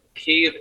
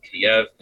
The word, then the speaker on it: Kyiv: I also want to mention for people listening, Kyiv is the Ukrainian pronunciation. Kiev is the Russian pronunciation. So we're now using Kyiv.